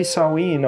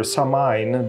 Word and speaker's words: Samhain: Uh, happy Samhain or Samhain, depending which pronunciation you pick.